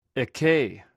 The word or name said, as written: ekei